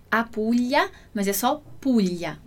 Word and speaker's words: Puglia: E a nossa pronúncia aqui do Puglia vai ser qual pronúncia? Vai ser do LH. Então, eu vou falar, né? Eu vou dizer esse som dessa forma, como se fosse, se tivesse escrito assim, Puglia. Mas aquele detalhezinho que a gente falou antes, a gente vai escrever no italiano Puglia, sempre com o GLI.